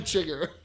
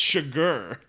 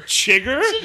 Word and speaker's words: Chigurh: Anton Chigurh. – That's not how you say it, you idiot. It's Chigurh. – Whatever. – Chigurh.